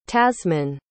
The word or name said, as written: tasman